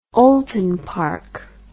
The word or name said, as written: oulton park